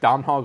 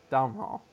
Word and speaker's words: Domhnall: Basically, the M is silent. So it's like Donal. – Yeah, Domhnall.